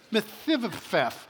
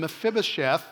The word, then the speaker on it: Mephibosheth: His name is Mephibosheth. You try to say that. I'll say it wrong every time I say it. Mephibosheth. You get it. The guy had a really rotten name to have to say every day of his life. Now, again, he was Saul's grandson, and Mephibosheth, that's how you say it, was crippled in both of his feet because he had a childhood accident, so he had a disability.